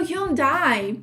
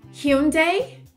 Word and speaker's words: Hyundai: Volvo tem o Hyundai, que o Hyundai, ele é pronunciado de duas formas diferentes, que é Hyundai ou Hyundai.